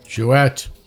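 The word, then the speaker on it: Jouët: Jouet. Ouais. It's Moët Chandon Perrier Jouet. You got an umlaut over the E, you pronounce the T.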